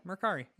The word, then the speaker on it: Mercari: Is that how you pronounce that? – Yeah, Mercari. Yeah. I bought stuff over there. – I've always pronounced it Merci because I'm weird.